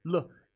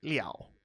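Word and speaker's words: Le (了): Now, this character can actually also sometimes be pronounced Liao, and that's its secondary pronunciation, so we don't teach secondary pronunciations when you first learn a character. You'll learn that later, but that Liao is also a very somewhat common usage of Le, but still, Le is way more common than Liao, so we'll go with L-E, fifth tone, so it's on the roof.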